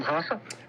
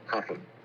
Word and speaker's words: Rafa: You pronounce your first name Hoffa? – Yes, that's correct. So in Portuguese, when the word begins with an R, it's pronounced like an H. – H. Okay. Hoffa.